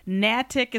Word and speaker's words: Natick: By the way, Natick is pronounced Natick.